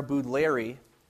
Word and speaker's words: Baudelaire: And so I want to start this morning by a line from a 19th century French poet named Charles-Pierre Baudelaire, which is the proper French pronunciation, of course.